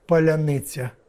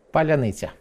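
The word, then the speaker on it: паляниця: На прощание, в смысле под конец, скажите, как правильно произносить слово «поляныця»? – Поляныця.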